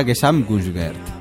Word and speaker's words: Gesamtkunstwerk: Era Gesamtkunstwerk.